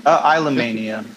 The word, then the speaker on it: Islomania: Islomania. How do I say this? Islomania? – Islomania. – Fuck, it's like a love of islands, you know? – I'm an idiot.